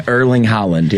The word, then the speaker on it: Erling Haaland: Is that how you say his name? – Erling Haaland, yeah. – So the G is silent. Erling Haaland. – Erling Haaland. H-A-A-L-N-D.